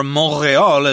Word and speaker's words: Montréal: So let's go to Montreal, or Montréal, as they say in French there.